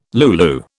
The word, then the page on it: lulu: British English